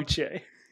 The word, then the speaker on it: Uche: Uche. Yeah. – Jesus, like, man. – Oh, is it somebody had like a take on take based on you saying Uche?